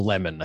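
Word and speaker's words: Lehmann: So I'm on a Unity ticket with Claire Lehman, or Lemon, I think she pronounces it, of Quillette, who's an Australian writer, editor of that, founder of that publication.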